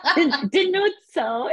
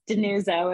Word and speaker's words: DiNuzzo: That's a good Italian pronunciation of it, yes. DiNuzzo, if you're feeling spicy in Italian. Just DiNuzzo, if you're American.